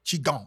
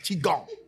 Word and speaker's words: Qigong: Again. Qigong. Mm-hmm. Y'all see I pronounced it correctly. Qigong.